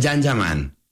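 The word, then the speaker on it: Can Yaman: Se escribe Kanyaman, se pronuncia Jan Yaman.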